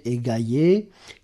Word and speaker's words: aiguayer: Ce verbe ne doit pas être confondu avec le verbe transitif, aujourd'hui inusité, égayer, AIGUAYER, prononcé égayer, qui signifiait tremper dans l'eau.